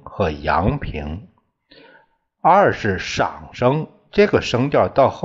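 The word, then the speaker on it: 上声: 上是这个念这个上下的上，这里念赏赏声，赏声是一个低平调，低声调，低声调。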